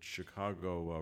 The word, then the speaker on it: Chicago: I found, for the first time in my rhyming career that Chicago rhymes with Iago. I didn't think it rhymed with anything.